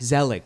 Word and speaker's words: Zelig: We can talk about Zelig. Z- am I pronouncing Zelig. right? Zelig.